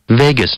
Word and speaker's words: vagus: Over the there's no line over the A here. – It's a short A in the Well, the computer says it's the vagus nerve, and I think the vagus nerve sounds pretty cool.